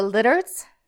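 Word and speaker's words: Lidérc: Lidertz. – The what? – The Lidertz. – Okay. – It's spelled L-I-D-E-R-C.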